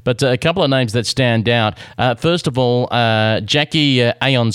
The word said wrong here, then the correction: Anion: Smith, or Anion Smith, I hope I get that correctly. – Yep, Anion, yep.